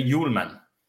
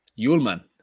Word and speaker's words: Hjulmand: You won't speak or pronounce the H. So. It's kind of like if you listen to the radio when they talk about Pierre-Emile Højbjerg, they always say, I mean, they get that pronunciation right. They don't say Bjerg or something like that. So, but you don't speak the H, and you say Hjulmand.